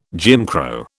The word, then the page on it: Jim Crow: British English